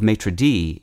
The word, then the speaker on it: maître d': For the record, the plural of maître d'hôtel is spelled with an S at the end of the word maître, but that S is silent as it would be in French, whereas the plural of maitre-DEE is maitre-DEEs, simply adding an S after the apostrophe.